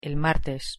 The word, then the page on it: el martes: Spanish